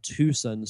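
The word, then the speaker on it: Twoson: When you get to the second town, which ironically enough is called Tucson, spelled with T-W-O, and Wynette is O-N-E, the third town is called Threed.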